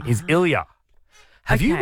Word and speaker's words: Ilya: Anyway, she has revealed that she has named her newborn son Ilya. Am I saying that right, Tom? Ilya? Yeah. – Ilya.